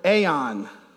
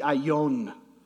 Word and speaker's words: aeon: Course here is the Greek word aeon. And if you were to actually pronounce it properly in Greek, it would be aion. But aeon's easier, so that's what we're going with.